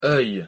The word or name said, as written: oeil